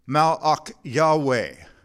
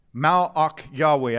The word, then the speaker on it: Malach Yahweh: In the Hebrew, that word is pronounced Malach Yahweh. Malach Yahweh.